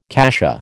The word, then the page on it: cassia: American English